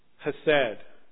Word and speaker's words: hesed: Loving kindness is from the Hebrew word hesed. H-E-S-E-D. That's the English pronunciation of hesed. The Hebrew pronunciation has that ch sound in it. That's not a sound we like to make in English. That's a sound that I just prefer not to make in English. So we'll say hesed.